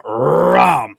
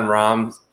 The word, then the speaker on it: Rahm: At the tippity tip top, you have John Rahm. 5.5-1. Can you argue that this is a good bet? – Roll that R on John Rahm.